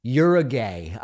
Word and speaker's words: Uruguay: And part of the flavor is Americans pronounce Uruguay in what seem like absurd ways. Uruguay, I found. So I'm just, it's just a reminder of the way that it's pronounced. Uruguay.